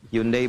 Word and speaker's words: Hyundai: Hyundai bacanya Hyundai.